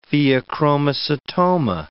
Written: pheochromocytoma

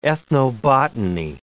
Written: ethnobotany

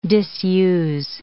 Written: disuse

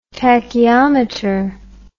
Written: tacheometer

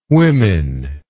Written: womyn

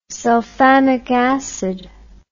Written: sulfamic acid

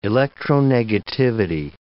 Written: electronegativity

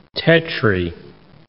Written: tetri

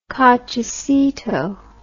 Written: cochecito

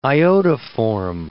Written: iodoform

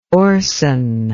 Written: orson

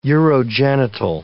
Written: urogenital

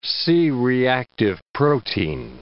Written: C-reactive protein

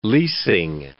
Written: leasing

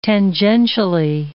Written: tangentially

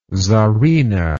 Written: tzarina

